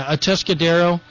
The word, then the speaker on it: Atascadero: And these are kids from the inner city, Atascadero, if I'm saying that right. – Yeah. – Wasn't it like Pinky Tuscadero a character? I don't know. Is that her? Yeah, Atascadero.